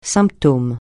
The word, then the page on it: symptôme: francais